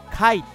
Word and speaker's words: KAI: ここで 2曲続けてお送りしたシンガーカイについて簡単にご紹介したいと思います。前回のオンエアを聞かれた方はお分かりの方もいるんではないでしょうか。前回のエンディングナンバー、こちら One Day をかけさせていただきました。アルファベットで KAI と書きましてカイと読みます。